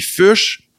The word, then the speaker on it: Fuchs: Puis Fush, moi je le prononce de même, mais c'est un nom allemand, fait que ça se prononce pas vraiment de même.